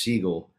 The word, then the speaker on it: Siegel: My real last name is Siegel. It's pronounced like the bird,